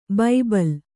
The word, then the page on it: ಬಬ್ಬುಲಿ: babbuli